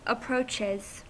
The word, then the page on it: approaches: American English